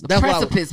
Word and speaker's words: precipice: I messed up earlier when I tried to say prefaces. See, I said it wrong right there. – I don't even know what you're saying. – The precipice.